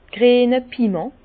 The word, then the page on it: d' griene Piment: Bas Rhin; Herrlisheim